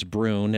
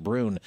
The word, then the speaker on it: Brunhes: The last name of the man is Brun. It doesn't look like that. It's Brunhes, for those who might want to look him up, but I think you say it's pronounced Brun.